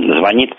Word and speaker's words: звонит: Иногда вот нечто считается неграмотным, скажем, ударение на первом слоге в глаголе «звонит». Здесь притормозили тенденцию, но она, безусловно, есть.